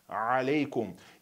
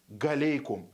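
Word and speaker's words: алейкум (عليكم): что здесь есть такой звук алейкум. И неправильно говорить галейкум, ассаляму галейкум, то есть или ассаляму галейкум. Вот так говорят, нужно говорить ассаляму алейкум.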